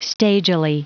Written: stagily

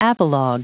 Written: apologue

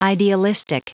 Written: idealistic

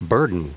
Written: burden

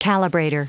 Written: calibrator

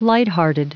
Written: lighthearted

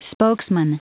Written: spokesman

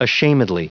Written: ashamedly

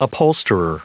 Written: upholsterer

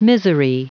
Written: misery

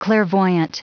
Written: clairvoyant

